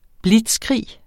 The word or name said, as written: blitzkrig